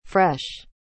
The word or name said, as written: fresh